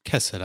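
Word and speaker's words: chesed (חסד): The Hebrew word for mercy is C-H-E-S-E-D-H. – Yeah, that's kesed, I think. – Okay. I like my pronunciation better. – You have to really the C-H. – You gotta get chesed.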